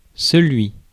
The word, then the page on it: celui: [sə.lɥi]; France